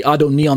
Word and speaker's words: Adoni: It's a title exclusively reserved for God, capital A-D-O-N-A-I. And on the left, you have lowercase A-D-O-N-I, which is pronounced Adon plus E or Adoni,